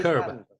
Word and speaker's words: Curb: Claro, a ver, Pruebe. diga curb. – Curb your, curb your enthusiasm.